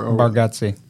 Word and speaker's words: Bargatze: Yeah, Barkowski or Bargatze.